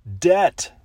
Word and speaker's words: debt: debt. D-E-B-T. The B is silent.